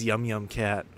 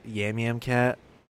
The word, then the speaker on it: Yum Yum Cat: This is Yum Yum Cat. Oops. Which I, like, could barely read back then, so I still can't read. I read it as Yam Yam Cat.